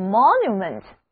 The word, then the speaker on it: monument: 那第二个包含 MON 这个词根的单词叫做 monument， monument 拼写是 MONUMENT，注意字母 O 读作 O，重音在第一个音节。